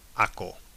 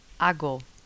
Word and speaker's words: hago: Now, with hacer, that would make aco. – Aco. – And that's not what it is. – No. – It's actually irregular. So, how do you say I make? – Or I do. – Oh, I do. – Hago. – Hago. – Hago. – Mm-hmm. So it isn't hago, it's hago.